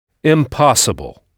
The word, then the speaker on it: impossible: Toujours avec la syllabe accentuée à l'oral placée avant le son able, impossible. Ça s'écrit donc comme en français, mais vous avez sans doute remarqué qu'en anglais, I suivi de M se prononce im, impossible. Tout simplement parce que le son nasal 1 n'existe pas en anglais.